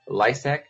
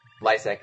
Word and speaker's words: Lisec: All you got to do is go to YouTube, search my name, Joshua Lysak. Lysak is spelled L-I-S-E-C.